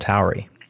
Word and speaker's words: Tauri: If you put me in a vacuum and said, pronounce this, I would probably pronounce it like Atari without the A. Tauri. That's probably less accurate than the way either of you are doing it, but I'm happy to call it Tauri.